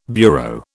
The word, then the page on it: bureau: British English